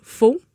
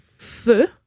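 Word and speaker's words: phở: Mais c'est ça, moi, ça me faisait penser à euh, un de mes repas préférés, qui est la soupe faux, prononcée feu. – ah oui, il faut prononcer feu? oui, c'est, ça dit qu'il faut le prononcer comme feu.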